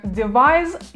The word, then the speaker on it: devise: Произносится как device, и вот здесь вы можете заметить более четкий, звонкий звук Z в конце.